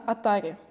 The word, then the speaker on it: Atari: Вот у тебя вот здесь прямо висит футболочка, где написано Atari. – Да. Да, я верно поставила ударение? – Все правильно.